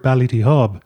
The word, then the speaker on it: Ballydehob: And, you know, if you take the actual word Ballydehob,